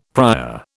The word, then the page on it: prior: British English